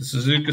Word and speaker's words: Suzuka: Is that the Suzuka Circuit? Am I saying that right? – The Suzuka Circuit, the Japanese Grand Prix.